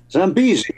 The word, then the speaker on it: Zambezi: Well, Norrie is uh, a pivotal person again on the next cut, which I hope I'm going to pronounce correctly as Zambesi. Zambezi, I think we would say. Zambezi. It's a river in Africa.